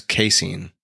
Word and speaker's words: casein: So if lots of people like to take, I'm going to look that up if it's casein or casein, that's bothering me. Hold on a second. All right. I am back. I've been saying it wrong this whole time. It's casein.